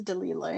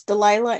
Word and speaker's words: Delilah: I mean, in Arabic, it is Dalila, yeah. But, I mean, it's Delilah in the English version of the book is Delilah.